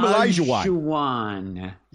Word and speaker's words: Hakeem Olajuwon: his name is Hakeem Olajuwon. Hakeem Olajuwon.